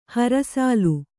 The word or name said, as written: ಹರಸಾಲು